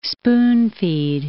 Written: spoon-feed

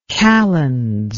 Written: kalends

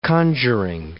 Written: conjuring

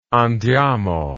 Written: andiamo

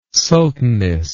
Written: sultaness

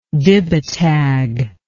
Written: dibatag

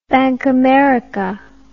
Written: bankamericard

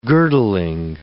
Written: girdling

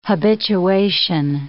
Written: habituation

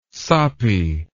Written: soppy

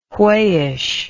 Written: wheyish

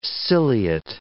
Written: ciliate

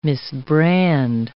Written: misbrand

